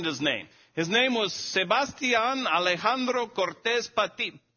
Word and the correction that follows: Patiño: Excuse me, now I'm getting his last name wrong. Sebastian Alejandro Cortez Patiño. The last name was the one I kept messing up before. I kept putting a D in there, and I did do it again.